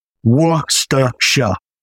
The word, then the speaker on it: Worcestershire: So, right, okay, it's not as complicated as what people think. It's just a couple of syllables. So it's Worcestershire. – Worcestershire. Okay. – That's it. I know there's a lot of Worcestershire and things when people say it, but just Worcestershire. That's it.